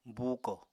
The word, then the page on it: buukò: Apatani